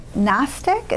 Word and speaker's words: Gnostic: Gnostic, is that how you say it? G-N-O-S, Gnostics.